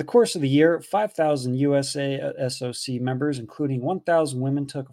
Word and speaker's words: USASOC: That's USASOC. That's how you pronounce that.